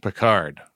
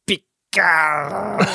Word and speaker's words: Picard: It's so weird to hear that name pronounced that way because I'm used to hearing it as Picard. – Sorry. Yeah. I kind of flubbed the pronunciation there.